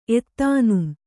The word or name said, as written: ಎತ್ತಾನುಂ